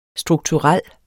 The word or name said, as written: strukturel